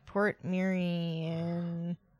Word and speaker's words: Portmeirion: Port Merion, Wales. It's so Port Merion, by the way, is spelled PORTMEIRION.